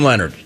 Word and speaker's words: Leonhard: There are a lot of names that are not pronounced the way they are spelled, including Jim Leonhard. – Jets. – Leonhard. – It's way more difficult than it needs to be.